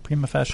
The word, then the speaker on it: prima facie: there's a prima facie case. How do you say that? Prima facie? – Prima facie.